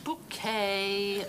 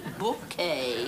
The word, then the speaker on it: Bucket: It says bucket on the envelope. It's bouquet, B-U-C-K-E-T, bouquet. The accent on the second syllable.